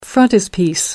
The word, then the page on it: frontispiece: American English